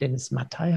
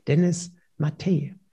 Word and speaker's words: Dennis Matthaei: Und dann habe ich erfahren, dass er nicht Dennis Mattei heißt, sondern Dennis Mattei.